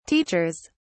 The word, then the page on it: teachers: British English